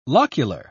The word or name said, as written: locular